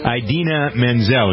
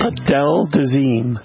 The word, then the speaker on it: Idina Menzel: He just butchered her name. – Adele Dazeem.